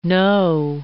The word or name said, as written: No.